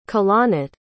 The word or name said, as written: kalanit